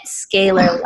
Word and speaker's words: scalar: How long have you been doing this as far as calling it scalar light? technology. Did I say it correctly? Is it scalar or scalar? – Scalar, that's correct.